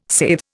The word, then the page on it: saithe: Indian